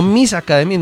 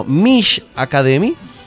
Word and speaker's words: Mish Academy: La academia, para que ustedes la vayan buscando en redes sociales, espero pronunciarla bien, Mish Academy, o como diríamos en español, Mish Academy, no Miss Academy, no, Mish Academy.